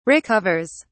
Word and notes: recovers: British English

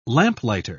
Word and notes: lamplighter: British English

